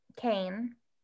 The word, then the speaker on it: Kane: Never was a man because I loved Kane. – Mm-hmm. – I almost said Kanye because it's spelled with the N and the Y. Like it took me a few chapters to realize that it's Kane.